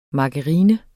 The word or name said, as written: margarine